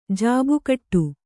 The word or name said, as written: ಜಾಬುಕಟ್ಟು